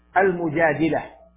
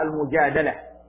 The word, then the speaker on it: المجادلة: فمن أسمائها المجادلة بكسر الدال، وهو الأكثر، وربما نطقهم بعضها المجادلة، وهو صحيح أيضا.